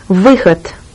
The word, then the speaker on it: выход: Выход.